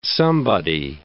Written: somebody

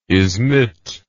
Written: izmit

